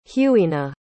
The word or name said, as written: hughina